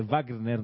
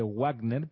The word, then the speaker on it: Wagner: Entiendo que por ser alemán el apellido se dice Wagner, no se dice Wagner, por más que aparezca escrito con doble V en, el idioma castellano.